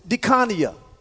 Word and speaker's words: diakonia: This word office that Paul used is the Greek word, I'm going to say it like this, diakonia. Or you can say diakonia, all right? D-I-A-K-O-N-I-A.